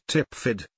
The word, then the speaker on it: TPFDD: The Mobilization and Deployment System, formerly called the TPFDD, pronounced TIPFID,